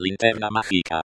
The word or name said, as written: linterna magica